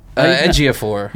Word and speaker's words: Ejiofor: Chiwetel, Ejiofor.